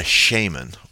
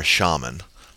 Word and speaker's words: shaman: Well, a shaman or a shaman, either pronunciation is correct, was a person in the group who they felt had special ties to the spirit world.